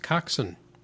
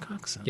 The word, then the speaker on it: coxswain: Yeah, C-O-X-S-A-I-N? W-A-I-N. Coxswain, which is the way you pronounced it. But it's pronounced coxswain. – It is pronounced coxswain.